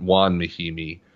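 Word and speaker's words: Mahinmi: Juan Mahimi, it's spelled Ian, but Juan Mahimi. – I'll pronounce it how I fucking see it. It's Ian.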